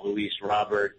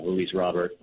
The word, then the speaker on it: Luis Robert: I've always heard it pronounced as Luis Robert.